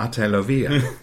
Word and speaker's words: Ate Lovia: which is kind of essential because it does look like eight Lovia or Lovia. There several versions of it, but Ate means um, big sister.